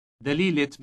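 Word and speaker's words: دليلة: Listen to the T sound inserted on its end. – دليلت Mr. Brown.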